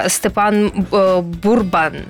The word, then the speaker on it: Степан Бурбан: В нас у гостях Степан Бурбан. Вітаю. – Дякую, правильно вимовили.